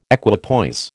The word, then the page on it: equipoise: American English